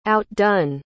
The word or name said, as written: outdone